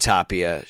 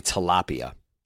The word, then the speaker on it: Tapia: Then Owen Miller and Tapia struck out. I always want to say tilapia, but that would be adding a syllable that doesn't exist.